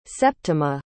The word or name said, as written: Septima